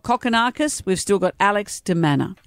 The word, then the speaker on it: de Minaur: Alex de Minaur. No, I'm not pronouncing that. – That's his name? – D-I-M-A-N-U-R. – De Minaur. – Or not Minaur. Let's not call him Manure, is still in.